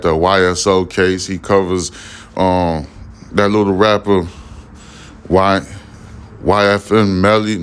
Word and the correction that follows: YNW Melly: YNW Melly trial case that got, I think he got a hung jury or a mistrial.